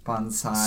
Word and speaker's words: bonsai: Bonsai. – So, trees. for those who aren't familiar, bonsai is the correct pronunciation for everyone out there.